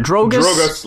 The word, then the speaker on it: Drogas: This album that we're reviewing this week, how do you pronounce this? Drogas? – Drogas Light.